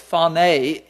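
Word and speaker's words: phoné (φωνή): It's interesting, the Greek word for voice is P-H-O-N-E. If we transliterate it into English, we would pronounce it phone. It's phoné in the Greek language.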